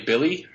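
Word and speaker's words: Willy: And on top of that, they're pushing Kristaps Porzingis to a position, especially with apparently Billy Hernan Gomez, even though it's spelled Willie, and adding and Kyle O'Quinn.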